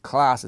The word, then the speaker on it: Klaas: Klaas is pronounced kind of like class.